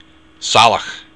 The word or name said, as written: salach